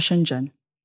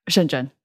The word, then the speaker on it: Shenzhen: Yes, I was born in China, in the south of China, right across the border from Hong Kong, called Shenzhen. – Oh, okay. You say that much better than I imagine I would. – Hmm. Shenzhen.